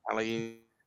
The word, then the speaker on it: Palhinha: So we did mention at this very start Palainia. Um, I've just butchered his name. Sorry, Palhinha.